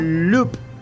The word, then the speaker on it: loupe: Mira, no sé bien cómo se pronuncia porque no sé francés, pero se escribe L-O-U-P-E. ¿Cómo será que se pronuncia? – L-O... – ¿Algo de francés tenés vos? – Loop. – Ahí está, loop, muy bien.